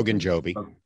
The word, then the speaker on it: Ogunjobi: Ogunjobi. – Ogunjobi,